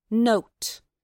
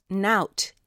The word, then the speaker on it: nowt: You'll notice the word spelled N-O-W-T is pronounced note, as if you took the word note, but slipped a bit of W sound in after the O. It's often mispronounced nout in audio versions of The Secret Garden.